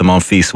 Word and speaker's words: Monfils: Home favourite Gael Monfils bowed out after losing a 5-set thriller with fellow Frenchman Hugo Gaston. – Monfils. Cameron, can I congratulate you on the pronunciation? It is a tour de force thus far.